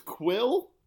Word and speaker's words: Kuiil: It's spelled K-U-I-I-L, and that's all. I think that's the way I heard it's pronounced. – That sounds like Quill to me.